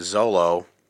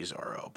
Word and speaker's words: Zoro: I meant Zolo. So, Mark, I hope you're proud of me that I said it right. It's actually Zoro, but I call him Zolo to piss off my friend Mark.